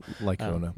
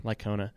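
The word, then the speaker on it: Licona: A guy named Michael, like, I don't remember, know how to say his name, Lycona or Lysona, I don't know. – Lycona. – Lycona.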